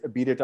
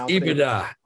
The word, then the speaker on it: EBITDA: So this week's word is abidida. A lot of people have pronounced. – It's pronounced EBITDA. EBITDA.